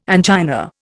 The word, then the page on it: angina: Indian